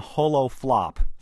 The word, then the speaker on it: HoloFlop: So the HoloFlip or HoloFlip, how do you say it? – We've come up with the name HoloFlop.